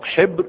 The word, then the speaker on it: حبر: وهي تنطق حبر وحبر، وكلا التعبيرين صحيح.